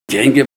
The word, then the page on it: d'eng'i: Ninilchik Russian